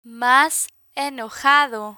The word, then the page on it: mas enojado: spanish